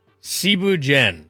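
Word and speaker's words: Thieboudienne: First off, Cebu Jen. It's spelt like Thibu Dien.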